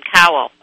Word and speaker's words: Cowell: How do you pronounce his name? Cowell.